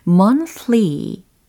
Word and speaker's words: monthly: monthly 발음하고 계십니까? monthly, monthly라고 하시면 돼요. 어, month 이거 먼저 완성을 해보시고 뒤에다가 ly라는 것을 붙이시면 됩니다. monthly, monthly, monthly, monthly 아니에요. month 이렇게 th 발음이니까 monthly.